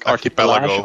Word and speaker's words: Archipelago: de Burning Archipelage, was de juiste uitspraak? Archipelago. Archipelago, Archipelago ja.